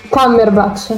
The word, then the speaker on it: Cumberbatch: Cumberbatch. Benedict Cumberbatch.